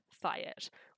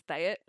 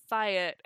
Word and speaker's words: Thayet: Also, I only just recently learned that it's pronounced Thayet. Like, for almost 20 years I've been reading it as Thayet,